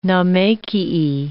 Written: naumachiae

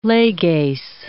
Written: leges

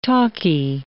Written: talky

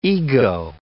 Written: ego

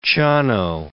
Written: Ciano

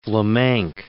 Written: Vlaminck

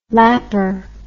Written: lapar

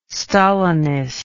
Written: stalinist

